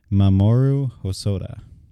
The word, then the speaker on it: Mamoru Hosoda: It is Mamoru Hosoda.